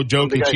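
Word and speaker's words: Jokić: Jovic? Joseph? – Oh, Jokic.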